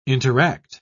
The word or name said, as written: interact